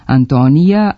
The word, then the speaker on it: Antonija: Također, u većini evropskih jezika ime generalnog sekretara Ujedinjenih nacija u takozvanim kosim padežima nema J, ali u srpskom ga ima, pa se izgovara i piše Antonija, Antoniju, Antonijem.